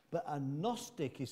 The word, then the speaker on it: Gnostic: It has a silent G on the word, Gnostic.